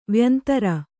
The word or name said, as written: ವ್ಯಂತರ